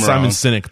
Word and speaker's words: Simon Sinek: So I think my favorite quote comes from Leaders Eat Last by Simon Sinek. Hopefully I didn't pronounce his name wrong. – Simon Sinek, but whatever.